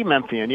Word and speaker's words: Memphian: It's the Daily Memphian.